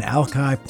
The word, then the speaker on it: Alki: Today, back in the day, November 13th, 1851, the Denny Party settled in Alki Point, Washington. Apparently, proper Chinook pronunciation is Alki, but no residents of the Washington area say it that way these days.